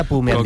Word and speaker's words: Bu: Yun Chaukete Bu. – Me han dicho que se pronuncia Pu.